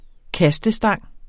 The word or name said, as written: kastestang